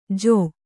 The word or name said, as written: ಜೂ